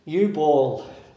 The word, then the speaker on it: Jubal: Eubaw, which is Jubal, but it's pronounced Eubaw. Eubaw is the first mention of music in the Bible in Genesis chapter 4.